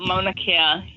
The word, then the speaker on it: Mauna Kea: is Mauna Kea? Is that how you say it? – Mauna Kea. Yep, Mauna Kea.